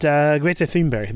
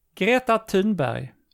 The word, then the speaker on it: Greta Thunberg: Greta Thunberg. – Thunberg. – That was very good. – Okay. Yes. Greta Thunberg.